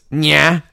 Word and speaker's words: Nyah: And the thing is, the way her name is spelled, it's Nya. – Yeah, it's like N-Y-A-H. Like the trash heap is spoken.